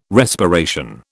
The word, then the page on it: respiration: British English